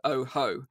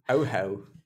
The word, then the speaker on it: Ojo: It's spelled Ojo, O-J-O, but pronounced Oho. – Oho, yes.